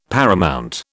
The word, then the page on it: paramount: British English